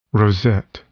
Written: rosette

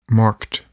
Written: marked

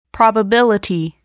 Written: probability

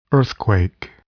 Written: earthquake

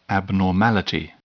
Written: abnormality

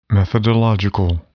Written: methodological

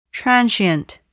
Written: transient

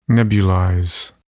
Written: nebulize

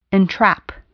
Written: entrap

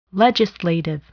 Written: legislative

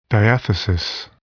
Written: diathesis

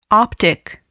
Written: optic

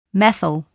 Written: methyl

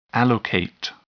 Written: allocate

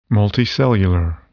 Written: multicellular

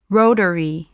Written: rotary

